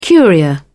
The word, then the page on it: curia: British English